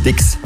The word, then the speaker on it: Styx: Die Band heißt Styx. Schon richtig ausgesprochen.